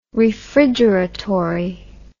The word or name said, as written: refrigeratory